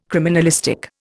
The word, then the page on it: criminalistic: Indian